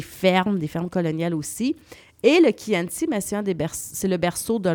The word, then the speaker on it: Chianti: Donc, ça s'écrit Chianti, mais on dit Chianti, qui est une région qui offre un splendide paysage parsemé de vignobles, de forêts de châtaigniers.